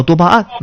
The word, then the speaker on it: 多巴胺: 我给大家发到群里，它是一件 3合1 的冲锋衣，颜色非常的多巴胺，正确读音叫多巴胺。